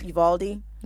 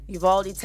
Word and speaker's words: Uvalde: And I want to say it's pronounced Uvalde. – Yeah. – Uvalde, Texas, at Robb Elementary School.